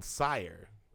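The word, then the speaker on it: Syre: He released a album called Sire, with spelled with a Y. S-Y-R-E.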